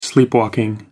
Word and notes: sleepwalking: British English

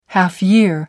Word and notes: half year: American English

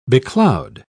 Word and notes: becloud: British English

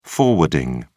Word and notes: forwarding: British English

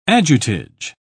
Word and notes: adjutage: British English

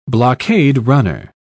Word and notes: blockade-runner: British English

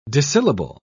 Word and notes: disyllable: British English

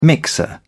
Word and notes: mixer: British English

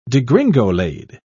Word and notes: degringolade: British English

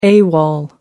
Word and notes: awol: American English

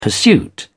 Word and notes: pursuit: British English

